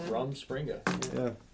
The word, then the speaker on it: Rumspringa: Yeah, there's your new topic for it's spelled for like rum and then spring. – Yeah, Rumspringa.